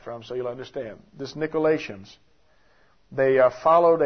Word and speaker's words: Nicolaitans: The Greek pronunciation is Nicolaites, something like that. Nicolaitans, that's the way we say it.